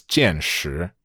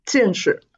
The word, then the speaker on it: 见识: I pronounced it wrong. It's not 见识, it's 见识. – 见识. The 识 doesn't have any tone.